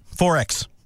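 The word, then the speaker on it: XXXX: 4X, pronounced 4X, is a brand of Australian beer brewed in Milton, Brisbane by Queensland brewers Castlemaine Perkins, now a division of the Japanese-owned Lion.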